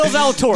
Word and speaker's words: Zalatoris: A guy by the name of Will, how do you pronounce this? Zale Torice? Will Zalatoris.